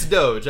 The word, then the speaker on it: Doge: I think it's Doge. I think it's Ophelius Doge. – I think you're right. It's D-O-G-E is how it's spelled, yep.